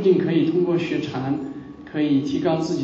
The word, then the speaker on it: 禅: 本来禅在中国的发音是禅，不是 Zen，